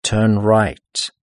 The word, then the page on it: turn right: British English